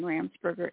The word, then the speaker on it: Ramsperger: All of my social media handles are at Kathy Ramsberger. That's Kathy with a K, A-T-H-Y, and Ramsberger is spelled R-A-M-S-P-E-R-G-E-R. There's no B in my name.